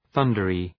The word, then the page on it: thundery: ‘ɵʌndərı